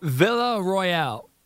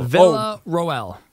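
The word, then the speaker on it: Villaroel: You know, Villa, how do you say that? You know? – Villa Royale. – Royale. – Villa Royale. – Oh, that's an O, not a C.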